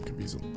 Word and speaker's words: Caviezel: Let me see how you say his name, Caviezel. – That's how you say it. – Yep. It's Caviezel, right? – Yep.